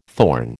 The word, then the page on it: thorn: American English